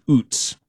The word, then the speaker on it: Utz: And the 630 here says, it's not Utz, it's Utz. Do you have a confirmation on pronunciation? I always thought it was Utz. It's U-T-Z.